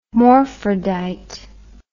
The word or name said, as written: morphrodite